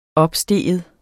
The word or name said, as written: opstige